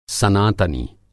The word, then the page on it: सनातनी: Hindi